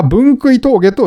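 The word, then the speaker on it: 分杭峠: えー、分食い峠。これ、分、踏み分け峠踏み食い峠とか言ってたんですけど、分け食い峠って言ってたのかなあの、先週の放送で。えー、これは分食い峠と読みますと。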